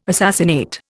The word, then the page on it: assassinate: Indian